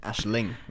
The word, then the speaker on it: Aisling: It's Ling, isn't it? Aisling, like Jay Ling's. – Yeah, I said that. But I'm just saying, I first called her Aisling.